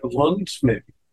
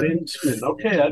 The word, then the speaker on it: Luensmann: That being said, our guest today, Matt Lundsmith. Did I say that properly? – Yeah, it's German, so it's hard. So Lundsmith is how I pronounce it. But Lundsmith, okay.